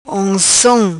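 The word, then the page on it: Oncom: patua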